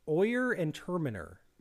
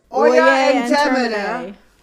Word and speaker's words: Oyer and Terminer: Governor William Phipps ordered the establishment of a special court of Oyer and Terminer. – You're pronouncing that wrong. It's Oyer and Terminer. – Oyer and Terminer? – Oyer and Terminer. – There you go.